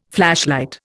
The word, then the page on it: flashlight: Indian